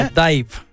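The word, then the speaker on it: DIIV: Bueno, ahí está Doused de esta banda conocida como Div o como Dive, como usted quiera. No creo que les importe, no nos están escuchando en este momento. – Según su página de Wikipedia, se pronuncia como Dive.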